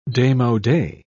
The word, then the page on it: demode: British English